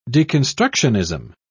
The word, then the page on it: deconstructionism: British English